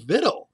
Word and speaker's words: victual: I said, but incidentally, you might be interested in knowing that believe it or not, the word victual is pronounced vittle.